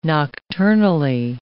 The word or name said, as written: nocturnally